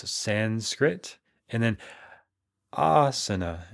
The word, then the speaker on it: asana: And I'm still surprised how often I'll have students who come from other teacher trainings and they still pronounce it asana. And it doesn't help that there's that productivity app, Asana. That's how they pronounce it and they spell it the same, but they pronounce it asana. So you may have seen that app. It's a to-do manager, a business productivity app. I actually use it in my business, but we call it asana.